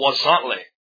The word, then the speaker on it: Huauzontle: And it's a plant called Wasantle. And it's not spelled anything like that. It's spelled H-U-A-U-Z-O-N-T-L-E. It's almost like Huzontli. Wasantli, I guess, is how it looks like it's spelled. But it's Wasantli.